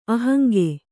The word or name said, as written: ಅಹಂಗೆ